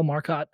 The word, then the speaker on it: Marcotte: It's Kyle Marcotte and then the number 9. – Oh, Marcotte. I'm sorry. I've been saying it wrong the whole time and you didn't correct me.